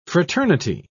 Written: fraternity

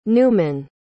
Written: neumann

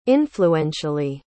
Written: influentially

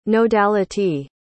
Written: nodality

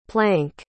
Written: planck

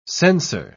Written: sensor

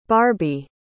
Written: barbi

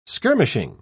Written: skirmishing

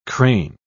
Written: crane

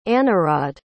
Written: anarawd

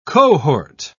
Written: cohort

